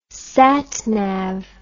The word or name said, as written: satnav